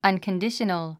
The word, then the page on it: unconditional: ʌ̀nkəndíʃənəl; アンコンディショノゥ